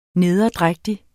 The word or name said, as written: nederdrægtig